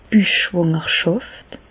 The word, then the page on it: d' Büchschwàngerschàft: Alsatian; Bas-Rhin; Reichshoffen